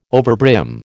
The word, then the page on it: overbrim: American English